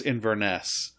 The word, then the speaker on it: Inverness: It's pronounced Inverness.